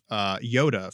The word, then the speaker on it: Yoda: Yoda from The Empire Strikes Back.